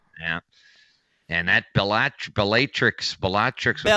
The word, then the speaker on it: Bellatrix: Bellatrix. – Oh, okay. – Yeah. – I tend to draw out the long A.